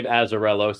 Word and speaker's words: Azzarello: how would you pronounce this? – I believe Azzarello sounds correct.